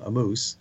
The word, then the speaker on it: Ammous: That's exactly how you pronounce his name, Ammous. And it's spelled A-M-M-O-U-S. A-M-M-O-U-S.